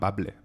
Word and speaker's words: bable: En bable, blabe no, em costa pronunciar, per això no ho diré.